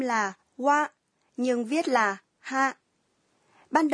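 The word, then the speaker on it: wa (は): Ngày xưa, trợ từ wa đứng sau chủ đề của câu được phát âm là ha và viết là ha. Phát âm của từ này dần biến đổi thành wa, nhưng cách viết vẫn là ha.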